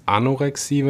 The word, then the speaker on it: Anorexie: Kannst du ganz kurz erklären den Unterschied Bulimie und ich glaube das andere, also die andere Essstörung, die so bekannt ist, ist Anorexie, wenn ich das richtig ausspreche. – Genau, ähm, richtig.